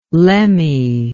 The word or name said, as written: lemme